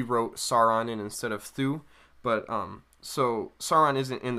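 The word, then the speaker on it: Thû: And Sauron is still not in it, but I think it's pronounced Thu. – Yeah. – T-H-U with an apostrophe is who Sauron takes a place of in the final version of it that you see in the Silmarillion.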